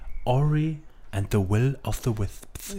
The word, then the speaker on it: Ori and the Will of the Wisps: Warte, du musst es richtig aussprechen. Ori and the Will of the Wisps.